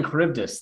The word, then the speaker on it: Charybdis: including Charon and the sea monsters Scylla and Charybdis. Pronunciation. Scylla and Charybdis.